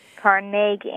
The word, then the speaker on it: Carnegie: Yes, and if you go to his little hometown in Scotland, that's what you'll hear again and again. – Carnegie. – Accent on the second syllable. – With more of an A than an E.